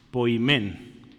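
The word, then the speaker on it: poimen (ποιμήν): Poimen. Ya no se le va a olvidar esta que parece N, ¿verdad? Se pronuncia como E poimen.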